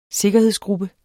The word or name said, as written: sikkerhedsgruppe